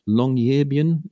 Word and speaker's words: Longyearbyen: But I'm going to pronounce this wrong, Longyearbyen, is that right? – Anyway. That's very close to precise Norwegian, even.